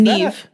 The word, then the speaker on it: Niamh: Is Neve. – That Neve? – Neve. – Okay, I didn't get that. – Is it spelled like the weird Irish way? – It's spelled N-I-A-M-H. – Yeah, that's Neve.